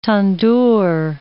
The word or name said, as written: tandoor